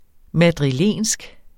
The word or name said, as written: madrilensk